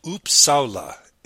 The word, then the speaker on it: Uppsala: So Dr. Dahl is an associate professor at the University of Uppsala in Sweden. Is that right? Did I pronounce that right? – That was good.